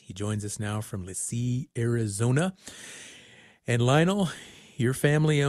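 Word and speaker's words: LeChee: Well, it's pronounced Lisee, but yeah, Lisee is a border town to Page, Arizona.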